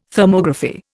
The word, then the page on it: Thermography: Indian